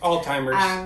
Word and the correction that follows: Alzheimer's: You know, it's Alzheimer's.